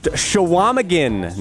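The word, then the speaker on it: Chequamegon: It is pronounced Shawamigan, Nicolet National Forest, on the Shawamigan Bay, up near Lake Superior, near the Apostle Islands. Dan in Brothertown and Corey, who lives in the Shawamigan Bay, also called to correct our pronunciation.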